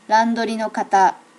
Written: randori no kata

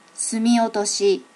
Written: sumi otoshi

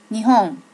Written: nihon

